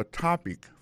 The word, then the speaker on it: topic: What's your topic for today? Alors, mot topic là, qui signifie sujet, ça veut dire accent tonique là tombé sous premier syllabe là.